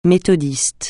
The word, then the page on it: méthodiste: francais